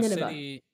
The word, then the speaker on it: Nineveh: I think, I don't know if Nineveh? That's how you say it. – That's, yeah, like Nineveh.